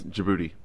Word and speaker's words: Djibouti: How do you pronounce the next one? J- did it's, uh, Djibouti? Uh, it's Djibouti.